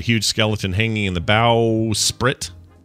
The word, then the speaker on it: bowsprit: Bowsprit. Oh, bowsprit. Yeah, bowsprit. – Bowsprit. Is that how they say it? – Hmm. – I don't know that I knew that. – I don't know what that is. I read that pirate as bow spirit, and I was thinking, oh, maybe that's like the thing, the thing that comes off the front of it, the bow.